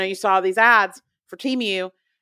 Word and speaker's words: Temu: And that is the brand Temu. That's how we pronounce it.